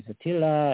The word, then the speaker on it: Attila: and I was always Attila,